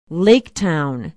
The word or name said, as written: laketown